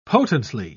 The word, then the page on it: potently: British English